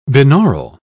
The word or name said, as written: binaural